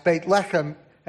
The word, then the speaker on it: Beit Lechem: And he writes, But as for you, Bethlehem, uh, Bethlehem if in Hebrew should be pronounced Beit Lechem, and it means house of bread.